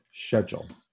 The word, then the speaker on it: schedule: It's a British show. It's pronounced schedule.